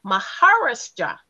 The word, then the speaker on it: Maharashtra: Maharashtra, Indian.